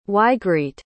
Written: ygritte

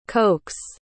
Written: cokes

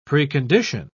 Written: precondition

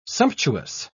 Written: sumptuous

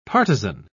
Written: partisan

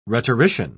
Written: rhetorician

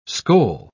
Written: skoal

